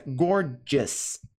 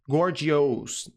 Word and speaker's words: gorgeous: Dica de pronúncia. Gorgeous. Us. Us. O, U, S, no fim de palavras, nunca é pronunciado ous. Gorgeous. Não. Sempre us. Então, gorgeous.